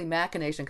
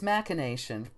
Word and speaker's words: machination: But I mean, if, it is technically machination because it comes from the Latin. And again, we've got the CH's K thing.